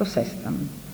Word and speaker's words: Sestan: E in Italia, a Firenze, si recava nel gennaio del 1919 per iscriversi alla facoltà di lettere Ernesto Sestam. Con l'accento sulla E troviamo scritto sempre il suo nome in tutti i registri del ginnasio e consentitemi oggi di fare altrettanto come erede del vecchio liceo.